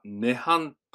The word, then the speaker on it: 涅槃: 漢字表記もこれあるんですが、それを日本では涅槃と読みます。ニルバーナは漢字で書くと涅槃になりますが、これは意味としては苦を、苦しみですね、苦を超越した心の平安を意味します。また、涅槃